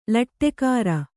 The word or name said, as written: ಲಟ್ಟೆಕಾರ